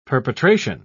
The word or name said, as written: perpetration